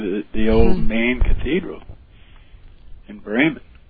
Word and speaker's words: Bremen: How do you pronounce Bremen, the city in Germany? Is that Bremen? – It's Bremen. Bremen. Bremen. – Bremen. – Bremen. – That's pronounced correctly.